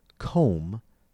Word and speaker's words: comb: In the word comb, C-O-M-B, the B is silent.